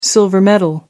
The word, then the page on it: silver medal: American English